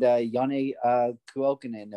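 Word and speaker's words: Janne Kuokkanen: And we're seeing players like Nico Hischier, power play goal, Yegor Sharangovich, shorthanded goal, Jack Hughes, goal assisted by Sharangovich, and Yanni Kuokkanen, if I said that correctly.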